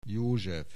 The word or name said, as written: József